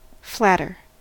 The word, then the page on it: flatter: American English